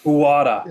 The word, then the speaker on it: Uada: How do you pronounce it? Like Yuda or UADDA? – The port. Yeah. – Uada. – Uada.